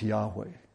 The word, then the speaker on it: Yahweh: Some pronounce it Yahweh, some pronounce it Jehovah. Jehovah, the pronunciation Jehovah really came as a result of a clerical error, a scribe's error. Don't tell the Jehovah's Witnesses that, but probably most likely is pronounced Yahweh.